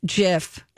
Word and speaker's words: GIF: And for his acceptance speech, he played a short video on the big screen that said, "It's pronounced GIF with a J, not a G."